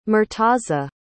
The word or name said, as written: murtaza